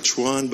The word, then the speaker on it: Chuan: It's spelled C-H-U-A-N. – Es ist C-H-U-A-N. – Okay. It may look like Chuan, but it's not. – Es sieht aus wie Chuan, aber es ist nicht. – And this is how you pronounce it. You cover my name and you just say Chuan.